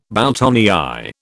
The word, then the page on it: boutonnière: British English